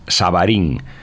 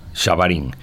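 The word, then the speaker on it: Xabarín: la pronunciación gallega tiene una X que, es, que se pronuncia como Sha, Shabarín. y entonces el chiste decía un poco como, pueden pronunciar Shakira, pero no pueden pronunciar Sanxenxo, o sea, Shabarín. Eh, Shabarín se pronuncia como Shakira. La Sh gallega es como la de Shakira es muy fácil, no hay por qué decir satélite Sabarín.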